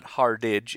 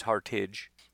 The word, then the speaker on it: Hartage: It's not Hardidge, it's Hartage. But cornerback out of Northwestern, so the Montre Hartage out of Northwestern is going to be that local visit.